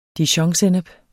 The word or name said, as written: dijonsennep